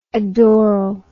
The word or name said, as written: adoral